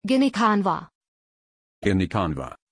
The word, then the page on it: Ginikanwa: German